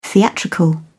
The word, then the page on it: theatrical: British English